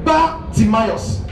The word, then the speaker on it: Bartimaeus: Now, I taught you that in the Bible, when I read the Bible and you see anything B-A-R, it means son. So the way we pronounce it is where the problem is coming from. Because we are saying Bartimaeus, we are not getting it. It is Bartimaeus. Bar means son. So son of Timaeus.